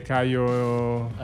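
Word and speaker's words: Kaio: E invece, parlaci di Gaio e Giorgi. Quindi abbiamo deciso che si dice Caio.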